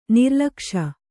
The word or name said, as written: ನಿರ್ಲಕ್ಷ